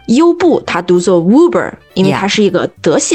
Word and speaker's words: Uber: 怎么说呢，很容易读成 Uber，但其实不是的。 – It's a German word, not an English word。 – 不是一个英语单词哈，是一个德语单词，所以直接读作 Uber。